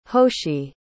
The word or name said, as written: hoshi